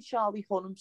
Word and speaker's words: Charlie Hunnam: Oh yeah, Charlie Hunnam.